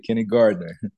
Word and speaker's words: Kenny Gardner: That's it, Kenny Gardner.